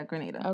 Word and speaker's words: Grenada: So, for instance, we have JFK to, is this Grenada? – Yeah, Grenada. – Okay, I didn't want to say it wrong.